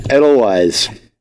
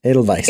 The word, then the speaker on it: Edelweiss: Edelweiss. – Yes. Am I saying it right? – No. – Edelweiss. – Yes.